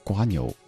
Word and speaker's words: 蜗牛: 比如说我们说。蜗牛，但是在台湾念的是瓜牛，所以你听到这首歌里唱的是瓜牛的家。